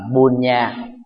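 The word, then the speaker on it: puñña: Trong thuật ngữ Phật học Pali, phước đức được phát âm là Punya, B phở U N N A,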